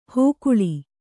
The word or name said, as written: ಹೋಕುಳಿ